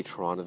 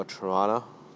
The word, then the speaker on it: Toronto: Or Toronto, not Toronto, the second T is silent.